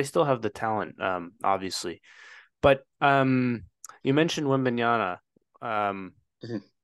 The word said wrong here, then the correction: Wembanyama: did I say did I say his name right? Wem Wembanyama, yeah.